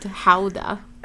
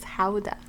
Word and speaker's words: Gouda: He would be named Houda. That's how you pronounce Gouda in Dutch. It's Houda. – Mm. Oh, he's coming over after you called him Houda.